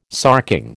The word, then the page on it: sarking: American English